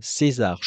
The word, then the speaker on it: Czesare: Ça s'écrit C-Z-E-S-A-R-E, mais on dit César, qui a sorti en juin son EP Alba.